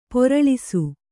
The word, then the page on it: ಪೊರಳಿಸು: poraḷisu; kannada